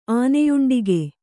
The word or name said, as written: ಆನೆಯುಂಡಿಗೆ